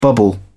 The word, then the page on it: bubble up: British English